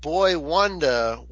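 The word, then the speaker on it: Boi-1da: I would think that Boy Wanda would be a member of Flo Rida's crew. – You are correct. It is spelled B-O-I dash the number one and then duh.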